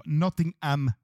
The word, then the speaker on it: Nottingham: På onsdag klockan ish 20.30 eh, Under the lights kliver Manchester United ut i åttondelsfinal i FA-cupen och där stöter man på Nottingham Forest. Har jag lärt mig att man ska säga. Man ska inte uttala H i Nottingham.